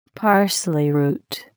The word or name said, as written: parsley root